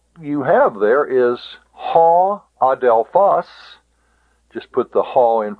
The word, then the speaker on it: adelphos: Ho adelphos. Notice the accent is on the last syllable.